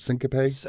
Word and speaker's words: syncope: Am I pronouncing this right? Syncope? – I think it's syncope.